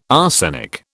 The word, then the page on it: arsenic: British English